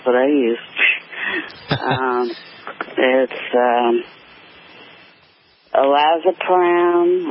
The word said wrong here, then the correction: lorazepam: or something like that. Lorazepam, I mean.